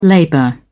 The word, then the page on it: labor: British English